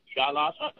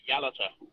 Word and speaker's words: Yalata: Just before I try and give an answer, you pronounce the town on the Nullarbor as Yalata. It's Oh. – actually pronounced Yalata.